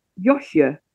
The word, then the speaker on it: Josje: JOSJE.